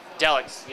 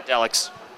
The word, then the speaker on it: Daleks: But it's the Derks, or whatever that freaking thing is from Doctor Who that we always do. Daleks. Yeah, Daleks. I suck, okay? I can't pronounce to save my life. You know this? Daleks.